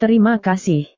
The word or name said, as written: terima kasih